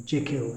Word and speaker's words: Jekyll: I'm going to talk little bit about Dr. Jekyll and Mr. Hyde, or Jekyll, if you want to pronounce it, more like the Scottish.